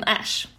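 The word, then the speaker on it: Æ: Men i alla fall, det uttalas tydligen Ash.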